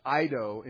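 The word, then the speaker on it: Eido (εἴδω): The verb, no one knows, the word knows, that verb, is eido in Greek, spelled in English, E-I-D, omega, long O. Eido, that's the uninflected term.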